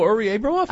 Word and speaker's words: Uri Abramov: Abramov, and I totally messed up his name. – Oh, Uri Abramov? – Oh, Abramov, there we go. – He's sorry. – Great. – Okay. I can't even pronounce English. Abramov, yes. Uri, yes.